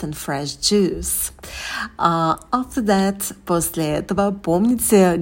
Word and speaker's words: juice: And fresh juice. В слове «juice» у нас долгий «у». Губы вперед не вытягиваем, а стараемся произносить этот звук и с оскалом.